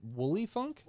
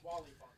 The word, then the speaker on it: Wally Funk: Pronounced Wooly. Wooly Funk? – Wally Funk. You got it right.